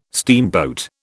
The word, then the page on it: steamboat: British English